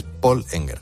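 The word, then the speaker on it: Pål Enger: Es que como es noruego, la A tiene este circulito encima que es una letra pues nórdica que se pronuncia como O. Es Paul Enger.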